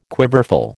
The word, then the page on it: quiverful: American English